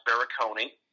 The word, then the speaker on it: Barakony: It's pronounced Barakony.